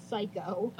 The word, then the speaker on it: Saiko: So it's spelled like Saiko, S-A-I-K-O, but it's pronounced Psycho.